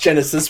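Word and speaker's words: Genisys: It's pronounced Genesis, but it was spelled like a child wrote it.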